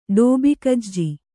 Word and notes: ಡೋಬಿಕಜ್ಜಿ: ḍōbi kajji

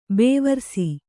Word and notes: ಬೇವಾರ್ಸಿ: bēvārsi; Kannada

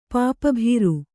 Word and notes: ಪಾಪಭೀರು: Kannada

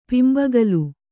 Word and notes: ಪಿಂಬಗಲ್: Kannada

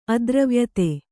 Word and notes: ಅದ್ರವ್ಯತೆ: kannada

